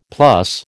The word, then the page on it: plus: American English